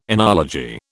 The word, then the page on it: enology: British English